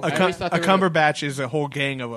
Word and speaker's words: Cumberbatch: If you put Benedict Cumberbatch in, God, I just butchered his name. – Benedict Cumberbatch. – Cumberbatch.